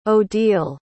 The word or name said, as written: odile